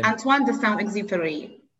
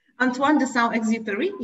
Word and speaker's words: Antoine de Saint-Exupéry: Antoine de Saint-Exupéry. – I'm so glad that you did that and I didn't have to. just say it one more time. – Antoine de Saint-Exupéry, yeah.